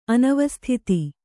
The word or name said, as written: ಅನವಸ್ಥಿತ